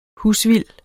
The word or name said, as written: husvild